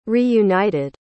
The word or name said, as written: reunited